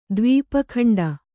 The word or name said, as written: ದ್ವೀಪಖಂಡ